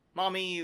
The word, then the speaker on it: Mami: Her name is, unfortunately, Mommy. – M-A-M-I, Mommy. – Yes, that's how you say it.